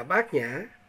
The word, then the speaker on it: Bát Nhã: Đúng theo chữ Ấn Độ là Bhajana, mà người Trung Hoa phiên âm ra chữ Bát Nhược, mà đọc thì đọc là Bát Nhã.